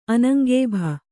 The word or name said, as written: ಅನಂಗೇಭ